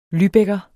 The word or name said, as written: lübecker